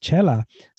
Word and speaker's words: Xela: So that X in Spanish produces the ch sound. And that's why it's spelled X-E-L-A. It still says Chella.